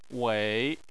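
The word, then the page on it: wei: tone 3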